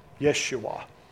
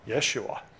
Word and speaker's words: Yeshua: It is the word Yeshua. Y-E-S-U apostrophe A-H. We spell that in America Yeshua. Y-E-S-H-U-A.